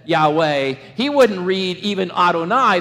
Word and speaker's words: Yahweh: YHWH. Yahweh, we pronounce it. But they never pronounced it.